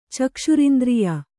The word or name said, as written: ಚಕ್ಷುರಿಂದ್ರಿಯ